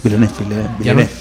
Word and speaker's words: Villeneuve: El otro día me comentabas que el director de Blade Runner 2049, Denis Villeneuve, uh-huh, ya lo digo bien, en 2018, Villeneuve. – ¿No? ¿No lo dijo otra vez bien? Joder, lo confundo con el de la Fórmula 1. – Te estoy troleando. Es Villeneuve.